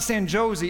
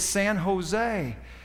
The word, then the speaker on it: San Jose: Because out in California, if it has a J on the front of it, they pronounce it with an H sound. So it's actually San Jose. I don't want you to go out there and get treated like an outsider because you don't know how to say the names and the words right. It's not San Jose. It's San Jose."